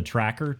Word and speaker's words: Trakr: It's called the Tracker, T-R-A-K-R, okay? I think I'm saying that right. – You are, yes.